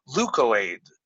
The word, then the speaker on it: Lucozade: In the UK, in the late 1920s, they designed something called Lucozade, L-U-C-O-Z-A-D-E is how you pronounce it.